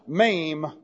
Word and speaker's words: Mem: And the next letter looks like this, and is pronounced Mem. This is equivalent to the letter M in the English.